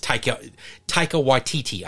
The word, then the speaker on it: Taika Waititi: I think it's Taika Waititi, I think is how you say it. – That actually sounds very accurate.